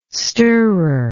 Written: stirrer

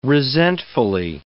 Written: resentfully

